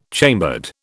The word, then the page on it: chambered: British English